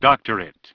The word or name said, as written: Doctorate